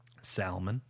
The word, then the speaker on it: Salmon: Colin Salmon. I'm sorry. How do you say it? – Salmon. – Thank you. Yeah.